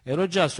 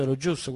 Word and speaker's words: Eurojust: Eurojust, o Eurojust, come si vuole pronunciare alla francese o all'inglese,